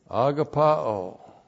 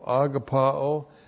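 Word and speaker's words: agapaō: Agapao. A-G-A-P-A long O. Agapao.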